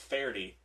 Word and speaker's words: Faherty: Yeah, well, the thing is, so, like, it's pronounced Faherty, but a lot of people say Faherty.